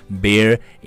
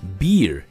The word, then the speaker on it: bear: 但是呢，就是说，哎，你要注意了，就叮嘱对方的时候，就可以说这个 bear in mind，千万不要读成 beer in mind。